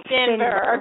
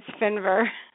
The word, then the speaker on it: Finver: It's Finver. – Oh, Finver. Okay, in like short I.